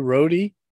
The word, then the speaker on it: Rohde: Yeah, um, I, I'll i watch uh, as much video as I could find on on the kid Andrew Rohde. Uh, I'm pretty sure that's how you pronounce his last name R O H R O H D E.